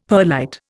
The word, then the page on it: perlite: Indian